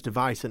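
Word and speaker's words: de Wijs: His cross deflected off of Hull City defender Jordi Dewidge. I think that's how you pronounce it. An own goal, but... – Yeah. No, it's Device, and I think it's... – Device? – Pronounced Device. – Jordi Device.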